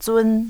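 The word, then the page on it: zun: language Chinese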